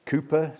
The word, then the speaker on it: Cowper: Oh, this wonderful hymn of Cowper's, Cooper, some would say. I'm sure it's spelled Cowper, but some say Cooper.